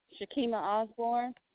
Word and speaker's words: Shakeema Osborne: Shakima Osborne. – Spell that, please. – S-H-A-K-E-E-M-A-O-S-B-O-R-N-E.